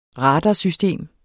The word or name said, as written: radarsystem